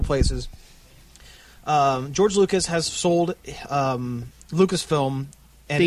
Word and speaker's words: Lucasfilm: We are talking, of course, about the Lucasfilm acquisition by Disney.